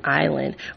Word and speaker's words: island: Man was like those silent letters they just learned about in English class, like the S in island or the G in phlegm.